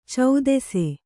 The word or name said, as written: ಛತ್ತೀಸ